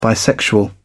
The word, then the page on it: bisexual: British English